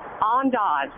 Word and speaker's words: Andaz: Andaz? – Andaz.